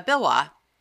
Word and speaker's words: Waugh: And that's his last name is spelled W-A-U-G-H. Wah? – Yeah, I think it's Wah. – Bill Wah.